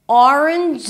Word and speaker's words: Orange: Now the first syllable is or. Or. Round your lips for the O. Make sure that you don't bring the tongue up too soon so it's not orange, but or. Or.